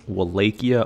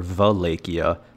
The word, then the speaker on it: Wallachia: First up in our history lecture is the region Vlad ruled, Wallachia, also pronounced Wallachia or Valachia, though I'll be using the first pronunciation for this episode.